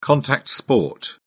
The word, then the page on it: contact sport: British English